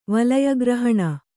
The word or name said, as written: ವಳಯಗ್ರಹಣ